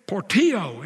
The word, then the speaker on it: Portillo: This is Sly and Michelle Portillo is how they pronounce that last name. Poor, P-O-R-T, and then O, Poor, T-O.